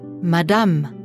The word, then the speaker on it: madame: Endet ein Wort zum Beispiel mit dem Buchstaben E, wird das E nicht ausgesprochen. Höre zu und spreche nach. – Madame. Banane. Salade.